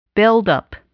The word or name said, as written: buildup